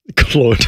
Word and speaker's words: Claude: Would you use Claude? – Claude. Why'd you say it like that? – That's his name. He's French.